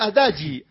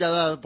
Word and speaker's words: Haddad: Pronuncia Adagi. – Adagi. – En Brasil se pronuncia Adagi. Acá le vamos a decir Haddad porque obviamente hablamos el español.